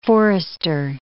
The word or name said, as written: forester